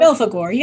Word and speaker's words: Belphegor: Belphegor, yeah.